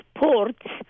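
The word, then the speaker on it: Porț: In Romanian, it's spelled P O R T with a little apostrophe under the T, which makes the pronunciation port. In Hungarian, it's spelled P O R C because the C in Hungarian is a sound of T but see,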